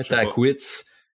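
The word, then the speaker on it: Tahquitz: Il y a deux grimpeurs en Californie qui grimpaient à Takwitz. que je sais même pas comment prononcer. C'est Takwitz. Taquitz. ouais, Taquitz.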